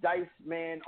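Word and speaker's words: Dyced Man: But with that being said, guys, Dice Man on the Twitter. Go follow him, D-Y-C-E-D, man.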